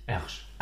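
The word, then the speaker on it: Hergé: Und hat aus R-G das einfach französisch ausgesprochen und nannte sich Ersch.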